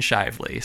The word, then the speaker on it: Shively: Now, here's how I know how to pronounce Shively. I wondered, I was like, is it Shively or something like that? And I had to look into it. And apparently their like slogan or like motto for their town is Lively and Shively. So that makes me know how you pronounce Shively, Kentucky.